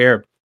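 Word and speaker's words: Hryb: Uh, Larry, and his last name is H R Y B. I don't know how you would pronounce that. Harb, Harb. – No, Larry, Larry Herb. Herb. Larry Herb.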